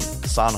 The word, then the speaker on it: San: Yeah, it's a long one, and another one just came out this week with San Holo as well. So I never know how to say his name, if it's San or Sam. – I'd say San.